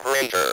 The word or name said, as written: greater